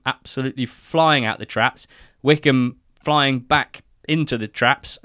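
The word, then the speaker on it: Wycombe: Tom, who is our Morecambe fan from Peru on the marvellous NTT20 squad, called this the Silent B Derby. Both Wickham and Morecambe having a rogue B in there.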